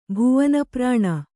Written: ಭುವನಪ್ರಾಣ

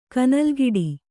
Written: ಕನಲ್ಗಿಡಿ

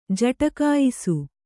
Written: ಜಟಕಾಯಿಸು

